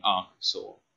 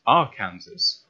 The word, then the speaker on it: Arkansas: It has Kansas what? In it. Why is it not said Kansas? Arkansas. – And it's like Saw is S-A-W. You know that.